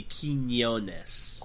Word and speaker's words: Quiñones: But the little squiggly on the N is an Ñe, so it's pronounced kind of how it sounds. So it'd be Quiñones.